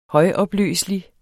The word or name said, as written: højopløselig